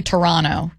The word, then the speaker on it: Toronto: You know, like Toronto, you don't say the second T. Did you know that? – I did not know that either. – Yeah, apparently Toronto, you say it like that.